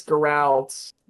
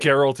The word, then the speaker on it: Geralt: It's Geralt. It is. – They pronounce it Geralt in the show and in the games, okay? – I know, I was just joshing with you.